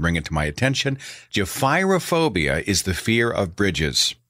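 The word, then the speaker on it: gephyrophobia: And I was talking about the fear of bridges, and I had mispronounced the term for the fear of bridges. I had simply read it. I got the phonetic wrong. I said it is Jephryophobia. It's Jephyrophobia. Jephyrophobia, Jephyrophobia.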